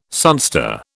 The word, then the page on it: sunstar: British English